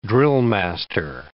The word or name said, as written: drillmaster